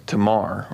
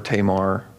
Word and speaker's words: Tamar: There's also a story in 2 Samuel about a man named Amnon who raped a girl named Tamar, or Tamar, however you want to pronounce it.